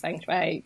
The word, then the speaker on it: Feng Shui: To be honest, that's probably the most difficult part of Feng Shui, really, just pronouncing it. But I pronounce it as Feng Shui. Lots of people pronounce it differently, but that's, yeah, just go with that.